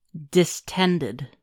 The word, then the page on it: distended: American English